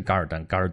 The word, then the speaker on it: 噶尔丹: 那个蒙古语里边发音是噶尔丹，噶尔丹。